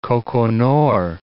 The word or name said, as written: Koko Nor